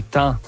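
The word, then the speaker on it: taons: ou ce qu'on appelle également les thans. Ou les thons, là. Il y a les deux prononciations, on va dire, au Québec qui sont acceptées,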